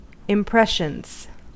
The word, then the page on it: impressions: American English